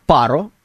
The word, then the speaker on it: Паро: Называется она «Паро». Именно правильно я поставил ударение на первый слог.